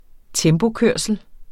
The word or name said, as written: tempokørsel